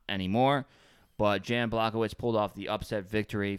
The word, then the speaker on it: Błachowicz: Błachowicz? I don't know if I'm saying that right. – Błachowicz.